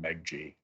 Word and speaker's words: Meghji: So it's Soltan Meghji.